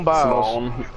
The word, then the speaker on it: Biles: Simon Bowles. Oh, I'm sorry. I, I did not mean to mispronounce a name like that. I said Simon too. Simone Biles.